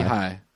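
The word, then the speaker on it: Lichaj: No, that's how it's pronounced. Lehigh. – Lehigh.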